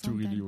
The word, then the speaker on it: Turilli: Turici. Je sais pas comment on le prononce. – Turilli, ouais.